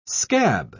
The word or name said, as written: scab